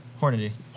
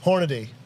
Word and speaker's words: Hornady: How do you guys pronounce that company that makes Hornaday. – That? – Hornaday. – How do you say it? – Hornady. – Hornady. Yeah. Some people say Hornaday. – Some people say Hornady. – Hornady. Yep. Hornady. Hornady. – Hornady is how I've always said it.